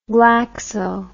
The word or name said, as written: glaxo